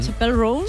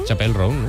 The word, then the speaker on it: Chappell Roan: Chappell Roan. – Chappell Roan, ¿no? – Ella misma nos explica cómo se pronuncia.